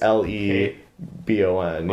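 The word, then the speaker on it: Cate Le Bon: Oh, uh, her name is spelled C A T E. – C A T. – And then it's okay. space L E B O N. Yeah. Le Bon. Okay.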